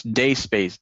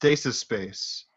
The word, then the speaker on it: Dacespace: Guys, just so you know, it's not pronounced Daces Space. It's a rookie mistake. It's Day Space, okay? – Thanks, PNG.